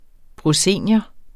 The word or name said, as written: proscenium